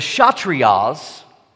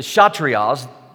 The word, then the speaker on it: Kshatriyas: Second, the shatir, uh, I'm not going to say this right, I'm sure. Uh, the Kshatriyas, that's how you say it, the Kshatriyas, that group is made up of soldiers and rulers.